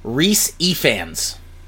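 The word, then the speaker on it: Rhys Ifans: The actor who plays the lizard, his name is pronounced Reese Ifans. – My phone says it all. – Yep. – Just why? Why spell it that way?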